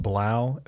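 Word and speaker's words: 3LAU: It's pronounced Blau, 3 LAU. – Really? – It's pronounced Blau, yeah.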